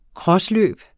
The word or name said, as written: crossløb